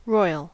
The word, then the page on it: royal: American English